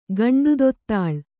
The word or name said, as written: ಗಂಡುದೊತ್ತಾಳ್